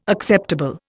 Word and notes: acceptable: Indian

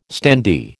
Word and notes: standee: American English

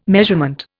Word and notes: measurement: Indian

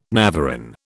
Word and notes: navarin: British English

